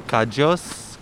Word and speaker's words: kadyos: We call them cajos, K-A-D-Y-O-S. – Okay, so it's spelled really different, but it sounds kind of similar.